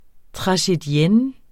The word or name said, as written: tragedienne